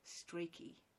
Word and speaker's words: Strachey: And Ottoline Morell, Dora Carrington, James and Alex Strachey, which I now know is how you say that.